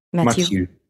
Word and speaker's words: Matiu: Matiu.